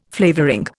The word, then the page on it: flavouring: Indian